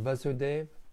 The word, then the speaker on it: Vasudev: Again, is that Vasudev a long A? No. Krishna first appeared before his parents, Devaki and Vasudev, in a four-handed form, and then he transformed himself into the two-handed form.